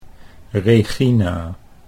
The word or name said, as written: Reegina